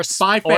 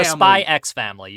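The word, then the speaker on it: Spy × Family: Or Spy Family. – Or Spy X Family. You can pronounce the X or you cannot. It's up to you.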